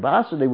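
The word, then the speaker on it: Vasudeva: Vasudev, the word Vasudev with a long A means Krishna, who is all-pervading, basically. The person who is all-pervading, and Vasudev, with a short A, means the father of Krishna.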